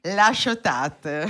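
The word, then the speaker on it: La Ciotat: La Chotate. Ringrazio Simona per la pronuncia.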